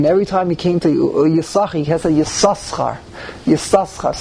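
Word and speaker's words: Yissachar: Yisachar is spelled in the Torah with two sins. So, and in fact, by Ashkenazim, we always pronounce it Yisachar. We ignore the second sin.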